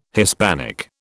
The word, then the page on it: Hispanic: British English